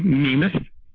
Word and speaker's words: Namath: I use the long E, Namath.